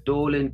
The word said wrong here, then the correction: Dallin: So you got the last name perfectly. The first name is Dallin. – Dallin, okay.